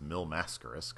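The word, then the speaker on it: Mil Máscaras: I thought his name was Mil Mascaris, but apparently, according to Vince, it's Mil Mascaris because that's how he pronounced his name.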